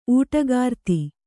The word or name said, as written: ಊಟಗಾರ್ತಿ